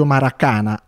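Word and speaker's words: Marakana: A lui è intitolato lo stadio Maracana, accento sulla penultima A di Belgrado, dove gioca la Stella Rossa, unico club in cui lui ha militato per 13 anni.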